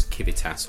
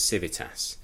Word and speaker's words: Civitas: We don't even pronounce it in the way that Latin scholars would have pronounced it, which calls Civitas or Civitas. We pronounce it Civitas, and that speaks volumes because it is very much our own word, it's our own concept, it's our own idea.